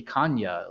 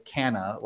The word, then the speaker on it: Canha: Kanya.